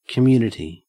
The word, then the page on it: community: Australian English